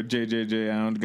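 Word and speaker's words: JJJJound: You think that's where JJJ Ound got his name? – I like that you don't pronounce the J and you said JJJ Ound. That's good.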